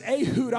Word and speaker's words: Ehud: One of my favorite characters in the Bible is in this same book named Ehud, and or some people pronounce it Ehud.